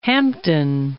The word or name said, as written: Hampden